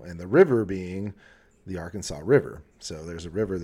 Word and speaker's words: Arkansas: It's codified as Arkansas.